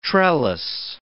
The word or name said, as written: trellis